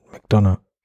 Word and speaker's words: McDonagh: Martin McDonagh, wie auch immer, also mit GH hinten, ich weiß nicht, wie man den Typen ausspricht. – Wahrscheinlich spricht man gar nicht, also Martin McDonagh.